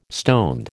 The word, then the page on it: stoned: American English